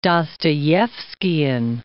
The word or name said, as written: Dostoevskian